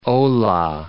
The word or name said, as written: Olah